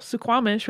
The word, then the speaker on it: Suquamish: So, for a little bit of background uh, about the Suquamish people, Suquamish, which is an Americanized pronunciation of their name, uh, actually means a place of clear salt water.